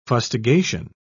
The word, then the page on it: fustigation: British English